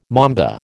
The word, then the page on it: mamba: American English